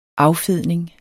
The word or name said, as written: affedning